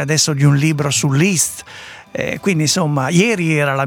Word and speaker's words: Liszt: Sarebbe Liszt. Molti sbagliano, però io sono andato a controllare la lingua ungherese. Effettivamente Liszt. – Liszt. Molto semplicemente.